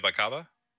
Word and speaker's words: Bakaba: Bakaba? I guess that's his name. Game director Dinga Bakaba. – Mm-hmm. That's how you say it.